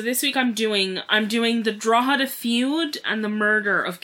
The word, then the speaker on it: Drogheda: Drogheda is spelled D-R-O-G-H-E-D-A. – Like Dunleary. – And it's pronounced Drogheda.